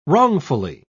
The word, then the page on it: wrongfully: British English